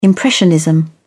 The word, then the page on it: impressionism: British English